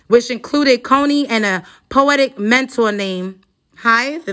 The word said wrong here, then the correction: Jive: Is it Hive? – Jive Poetic.